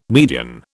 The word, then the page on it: median: British English